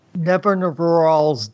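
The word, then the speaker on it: Nevinyrral: Niven Rural's disc. – Right. And that's Larry Niven backwards.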